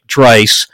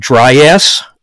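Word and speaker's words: Draiss: Well, my last name is spelt D as in David, R-A-I-S-S. It's pronounced with a long I, like Dreiss. I've been called Dryass. Drace, hey. Drass, Drouse, and some people on the customer service, I'm like, you're not even trying.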